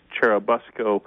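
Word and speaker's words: Churubusco: Yeah, the original town, I'm told, in Mexico is called Cherubusco, but the two towns in the United States that are so named after it are generally pronounced Cherubusco, or if you want to be brief, just Busco.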